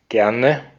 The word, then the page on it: happily: /ˈha.pə.li/; /ˈhæ.pə.li/